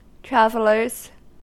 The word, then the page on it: travelers: American English